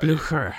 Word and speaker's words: Blücher: You pronounce German way better than Blücher.